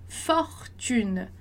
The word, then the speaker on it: fortune: Lembra lá da aula anterior do O, do som do O que a gente teve, quando eu falei que quando tem duas consoantes, o O anterior vai ser pronunciado com o som de O. Então, a gente fala fortune, tá? Não vai falar fortune, igual no português, fortuna, né?